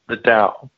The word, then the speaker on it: Tao: The Tao.